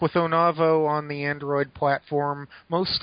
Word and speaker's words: Onavo: Is it Onavo? – I yeah, think O-N-A- yeah, I believe it's Onavo. Uh,